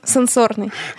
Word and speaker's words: сенсорный: И тогда я залезла в справочник и увидела, что сенсорный — это все, что касается техники, и сенсорный — это все, что касается нашей физиологии и так далее, чувств, ага.